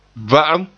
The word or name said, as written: va'am